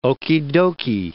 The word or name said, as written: okeydokey